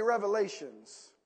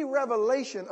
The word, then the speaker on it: Revelation: You know, the book of Revelation, a lot of people mispronounce it, and that's okay. I'm not going to talk about you. But a lot of people just say revelations.